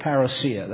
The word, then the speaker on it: parousia: Ordinarily, I don't mind anglicizations of words, but that's too much for me. So we'll stick with parousia. That's the way it's supposed to be pronounced.